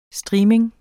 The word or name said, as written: streaming